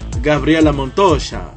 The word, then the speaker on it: Gabriela Montoya: O Mundo Latino com a Gabriela Montoya, ou melhor, pronunciando a Argentina, Gabriela Montoya, que é como dizem os argentinos.